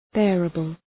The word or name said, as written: bearable